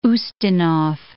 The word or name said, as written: Ustinov